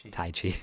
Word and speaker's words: Tai Chi: Good looking out, Chai Chi. Chai Chi. How do you say that? – Tai Chi. – Chai Chi is good too.